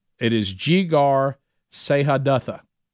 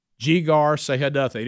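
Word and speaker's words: Jegar-sahadutha: I think it is Jigar. We say that. Jigar is the way that you say it. I've looked at some pronunciations of this. And then if anybody wants to take on that second part, you're more than, and it is Jigar Sehadatha. Jigar Sehadatha.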